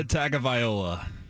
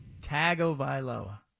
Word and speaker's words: Tagovailoa: Tua Tagovailoa. – Tagovailoa. – Yeah, that was good. First one was bad.